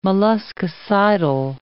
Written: molluscicidal

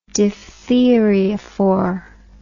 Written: diphtheriaphor

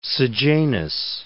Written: Sejanus

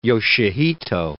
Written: Yoshihito

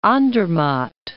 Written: Andermatt

